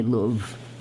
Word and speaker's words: Louvre: I don't know how to say Louvre. – You said it right.